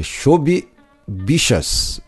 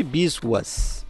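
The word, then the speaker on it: Chhabi Biswas: Eu tava vendo a pronúncia, né? Tentar, vou gravar o podcast, vou tentar falar. É Shobi Bichas, bichas. Shobi Bichas. – Esse é o nome dele. Eu vi chamando de Biswas. – Não, Biswas. – não, mas a pronúncia, eu tô falando a pronúncia, bichas.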